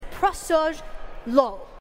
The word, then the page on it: prosuj lo: Phyrexian